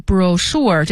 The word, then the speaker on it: brochure: 好，再往下呢是brochure brochure 这个词呢是 B-R-O-C-H-U-R-E, C-H 发诗的音，所以念成 brochure, 小册子，说明书，产品宣传册，都是 brochure。